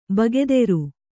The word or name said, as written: ಭಾಗೇದಾರ